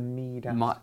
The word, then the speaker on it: Midas: The Midas. – Midas. – We pronounce it Midas. I mean, Midas is more accurate for the Greek us.